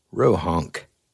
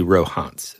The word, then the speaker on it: Rohonc: Okay, now, when you look this up, this book, the way it's spelled is R-O-H-O-N-C. So whenever I found out about this book, I thought it was the Rohans Codex, right? Mm-hmm. But it's actually Rohans, and that's how you pronounce it.